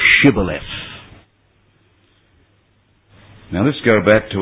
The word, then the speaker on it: Shibboleth: they were told to pronounce the word Shibboleth, S-H-I-B-B-O-L-E-T-H. This was because their dialect couldn't pronounce the SH in the word Shibboleth. And instead, they would say Sibboleth.